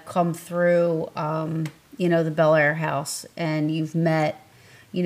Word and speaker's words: Bel Air: So, of the people that have visited, and you, and I'm sorry, this might just be location or you know, what is it pronounced Bel Air or is it Bel Air? – It's Bel Air. – Okay. – But the local cobbler, like a bodily function. – Got. Gosh. I don't want to say it wrong. – Yeah, it's just like Bel Air, just like the Fresh Prince, for real.